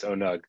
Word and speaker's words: ONUG: I thought it was ONUG. So I'm glad to hear it's ONUG.